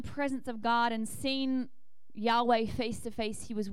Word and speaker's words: Yahweh: So he built an altar and named it Jehovah, or uh, that's our pronunciation, but in the uh, as we know a little bit more and better Yahweh.